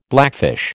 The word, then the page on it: blackfish: American English